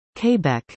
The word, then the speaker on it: Quebec: The correct pronunciation for non-natives is Quebec, while French speakers would say Quebec.